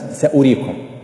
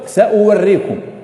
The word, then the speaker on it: سأريكم: ومثل سأريكم دار الفاسقين، سأريكم مكتوبة واو مع أنها ما تنطق، وإن الذي ينطق الضمة التي على الهمز سأريكم، لكن موجودة واو سأوريكم. لو قرأتها كما هي مكتوبة سأوريكم، ويستشهدون بها مثلا على اللهجة اللي أوريك ولا، لكنها لا تنطق.